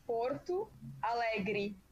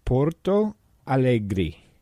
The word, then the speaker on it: Porto Alegre: Okay, my hometown is Porto Alegre. – Porto Alegre.